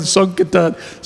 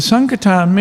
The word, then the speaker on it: Sankirtan: He wrote me, I'm going on Sonkartan. So he spelled it S O N K A R T A N. I thought, oh, that's interesting. That's how he pronounces it.